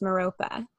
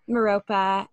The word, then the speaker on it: Merope: and I guess people pronounce the name Merope, but for some reason it always comes to me as Merope, like an only Merope, and they tell me that that is the only correct way to say it. So there we go. So it's Merope, and yeah, the heart chakra, and holding the energy of the heart chakra.